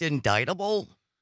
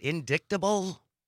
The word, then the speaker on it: indictable: It's actually pronounced indictable. – Yeah. – An indictable crime. – Not indictable. Small thing.